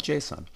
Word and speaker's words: JSON: Some call it JSON, others call it JSON. I call it JSON. I pronounce the O heavily.